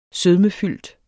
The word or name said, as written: sødmefyldt